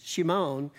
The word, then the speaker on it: Shimon: Shimon is how it would have been pronounced.